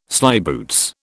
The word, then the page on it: slyboots: British English